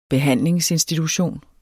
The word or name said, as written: behandlingsinstitution